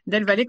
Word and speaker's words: Delvalet: Delvalet comme une vallée, hein, et eux, voilà.